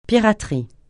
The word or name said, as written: piraterie